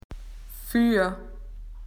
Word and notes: in front: Australian English

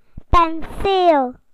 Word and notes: paintbrush: British English

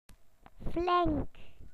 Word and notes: well done: British English

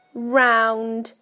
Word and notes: round: British English

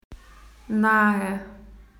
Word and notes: near: Australian English

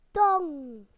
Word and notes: tongue: British English

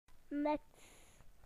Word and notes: hat: British English

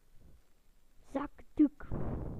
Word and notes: tissue: British English

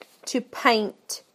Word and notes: to paint: British English

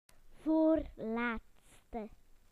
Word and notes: second to last: British English

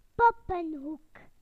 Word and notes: dolls corner: British English

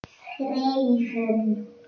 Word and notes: to write: British English

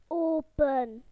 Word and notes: open: British English

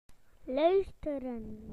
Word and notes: to listen: British English

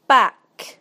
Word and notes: back: British English

